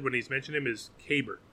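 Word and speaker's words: Keibert: So, in February, they sent out a guide with like the proper like accent marks and some pronunciations for players, and for the Dodgers catcher, it says K-E-Y in all caps as the accented syllable, and then B-E-R-T. – Kybert.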